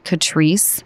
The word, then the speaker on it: Catrice: First of all, it's, I believe you pronounce it Catrice, okay. C-A-T-R-I-C-E.